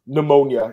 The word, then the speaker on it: pneumonia: Pneumonia. I think pneumonia. The P is silent, right? It's weird.